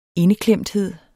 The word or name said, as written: indeklemthed